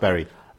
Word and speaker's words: Bury: The Bury Theatre, sorry. Well, no, it's Bury. – It's pronounced Bury. – It's like Derby and Derby again.